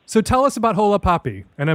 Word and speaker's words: Hola Papi: No, you know, it's a little bit of a gringo accent, but otherwise you're right on the money. Hola, Papi, it's an interesting column.